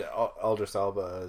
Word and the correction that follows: Idris Elba: that's how you pronounce his name, right? – I don't know. Idris Elba.